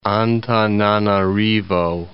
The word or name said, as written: Antananarivo